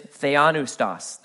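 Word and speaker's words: theopneustos: That all Scripture is theanoustos. That's how you pronounce that first word there.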